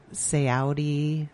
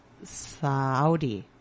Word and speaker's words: Saori: S A O R I. And I always have to do that pronunciation thing that where they say it, but I think it's Saudi.